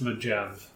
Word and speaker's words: Mojave: Pronounce Mojave.